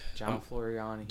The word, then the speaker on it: Floriani: So that was, how you say his name one more time? – John Floriani. – You know I can't pronounce words. – John Floriani. – John F. – He's Italian. – John, that's why I can't pronounce it. It's like their names are kind of difficult sometimes. – John Floriani. – Floriani. Okay, I said it right.